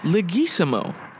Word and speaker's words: Leguizamo: So, I gotta start off with one of my fucking legends, Mr. John Leguizamo. Yes, I pronounced it correctly.